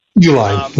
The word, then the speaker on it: Uline: And the Uline, is that how you pronounce it? – Uline, yeah.